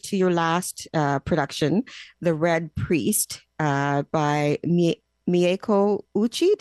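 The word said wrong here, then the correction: Mieko Ouchi: Did I say that right? – I believe it's Mieko Ochi. – Mieko Ochi. Yes.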